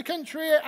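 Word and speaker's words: Cenchreae: I realized as I was reading the passage, that I'd spent no time thinking how you pronounce Kentria. So this is how I'm going to pronounce it. He comes to Kenchrea, and there we see this curious information that he shaves his hair off because of a vow that he'd taken.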